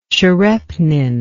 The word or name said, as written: tcherepnin